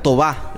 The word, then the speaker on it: Toba: En realidad, Toba, la pronunciación original eh, aguda, Toba.